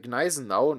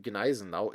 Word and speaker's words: Gneisenau: Especially in English-speaking circles, there is sometimes the theory that the G in Gneisenau is silent and not pronounced, and that the name should therefore be pronounced Gneisenau. Even in debates with Germans, this theory is sometimes stubbornly maintained. Apart from a few H letters, there are actually no silent consonants in the German language, as for example in Thron, and virtually none at the beginning of words.